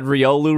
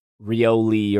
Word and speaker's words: Riolu: She said Riolu wrong and somebody corrected her and she was like, oh, that's how you say it. Cool. Like she was a very, what, surprisingly, it was not me who corrected her. I think she called it Rioli or something like that.